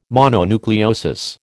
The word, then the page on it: mononucleosis: American English